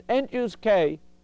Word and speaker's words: n choose k: Okay, and So somehow when you think of what it, binomial coefficient it means n choose k, which is how I pronounce it, that means picking k things out of n.